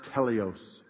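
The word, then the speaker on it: teleos (τέλειος): It's a little Greek word that's pronounced teleos.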